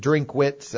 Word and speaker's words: Drinkwitz: Drinkwitz, actually. I think everyone kind of throws an extra syllable in there, but, He just said that's like, you know, like that's their decision.